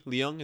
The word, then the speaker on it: Leung: One person I haven't mentioned that I need to shout out, Tony Leung, is that how you say it? – Yeah.